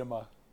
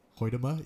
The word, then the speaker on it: Hoytema: It's Hoytema. – Hoytema, yeah.